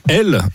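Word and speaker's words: Hel: Elle, une petite ville balnéaire au bout d'une presqu'île, hein, sur la Baltique, dont le nom se prononce exactement comme Hell en anglais, soit l'enfer.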